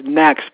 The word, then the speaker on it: NECST: It's pronounced next, but that's spelled that way.